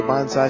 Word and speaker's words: bonsai: Yeah, I mentioned we were going to talk to some folks at the Bonsai Society, and I learned something right away, and that is you don't pronounce it that way. Why? You pronounce it Bonsai.